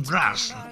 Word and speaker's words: Mraz: Ahí está sonando Jason Mraz. ¿Cómo se escribe? Se escribe M-R-A-Z, pero ¿cómo se pronuncia esta cuestión? Siempre pienso, porque la M, la R, Mraz, Mraz, suena raro. Jason Mraz, qué demonios.